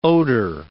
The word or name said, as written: odor